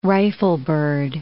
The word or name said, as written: riflebird